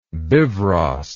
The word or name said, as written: bifrost